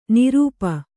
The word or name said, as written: ನೃಪ